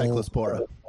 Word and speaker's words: cyclospora: I mean, honestly, like literally every day now, the psychosopolis, I can't pronounce it, is still cyclospora.